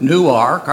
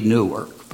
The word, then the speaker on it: Newark: I didn't remember anything about them other than they left Newark, Arkansas, for Phoenix, Arizona. But they wanted to be buried back in Newark. Incidentally, in New Jersey, that would be Newark. But in Arkansas, you better say Newark.